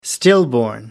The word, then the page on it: stillborn: American English